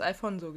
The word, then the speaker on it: Alfonso: Alfonso, habe ich es beim ersten Mal richtig ausgesprochen? Oder habe ich da mhm. Alphons gesagt? – Du hast Alfonso gesagt.